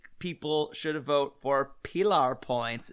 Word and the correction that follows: Pillar Point: Why do you say it like that? – Pilar. – It's what? – Pillar. – Am I saying it? – It's pillar. Pillar point.